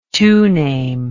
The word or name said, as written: toname